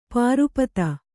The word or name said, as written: ಪರಿಪೀತ